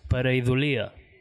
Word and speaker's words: Pareidolia: Vinga, es pronuncia Pareidolia, vale? – Joder, manxo.